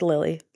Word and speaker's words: Lili: Because it's spelled L-I-L-I. People have said Lili, Lily. No hate. I love you all. You're close. It's just Lily, like the flower.